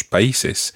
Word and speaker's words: país: Now, that word país is a word that people often pronounce wrongly. País. – País. – And in the plural, países. – Países. – And it's the accent on the I that makes it país. País. – País. – It's spelled P-A-I with an accent S.